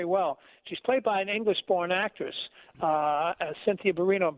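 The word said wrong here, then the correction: Erivo: but it's a compelling movie. It really is. I should say her name is Arrivo.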